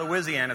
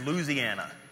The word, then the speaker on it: Louisiana: But if you're from there, it's not Louisiana, it's Louisiana. That's how you say it.